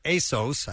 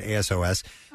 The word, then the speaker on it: ASOS: Currently, the youth-leaning retailer ASOS, I guess that's how you say it, ASOS, sells over 200 styles of men's jean shorts online for as low as $10.